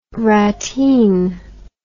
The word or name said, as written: rateen